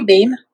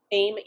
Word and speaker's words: Baim: Baim? – Baim, yeah, Aim with a B.